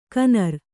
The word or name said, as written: ಕಣ್ಣುರಿ